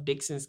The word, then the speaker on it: Dixson: I am the owner and head chef of Dixon's Catering and Meal Prep. That is Dixon, D-I-X-S-O-N. That's D-I-X-S-O-N. People spell my last name wrong my entire life. It has an S.